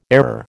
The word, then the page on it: airer: American English